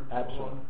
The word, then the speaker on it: Absalom: Absalom.